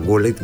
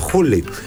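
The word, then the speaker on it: Gullit: Το G στα Ολλανδικά προφέρεται Χ. Φίλοι Ολλανδοί δεν καταλάβαιναν όταν έλεγα γκούλιτ, με διόρθωναν και έλεγαν χούλιτ.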